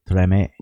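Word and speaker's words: Tremé: Like Treme? – Treme. Treme. I think the accent's on the first syllable.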